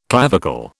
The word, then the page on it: clavicle: British English